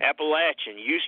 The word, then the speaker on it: Appalachian: Appalachian. You're pronouncing it correctly. There are a lot of people, especially educated people, who say Appalachian. But down here, we all say Appalachian.